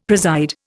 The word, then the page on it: preside: Indian